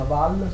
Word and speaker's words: Valls: In realtà il primo ministro francese Val, si pronuncia Val, scritto Valls.